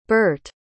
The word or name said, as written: Bert